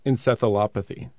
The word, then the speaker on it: encephalopathy: Specifically, how they can lead to CTE, which stands for chronic traumatic encephalopathy.